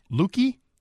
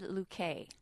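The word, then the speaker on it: Lékué: Leaky? Leaky? – I said Luque. – Luque. – How do you spell it? I'll be the judge. – L-E-K-U-E, but it has the little like...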